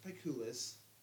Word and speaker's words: Picullus: Yes, my Instagram, you can find me on Instagram at P-I-C-U-L-L-U-S. It's pronounced Piculus.